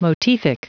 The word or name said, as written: motific